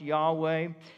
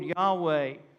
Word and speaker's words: Yahweh: The exact pronunciation of God's name in the Old Testament was lost in antiquity because the Jews considered it too sacred to pronounce. So we believe it was probably pronounced Yahweh.